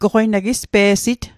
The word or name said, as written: pe'sit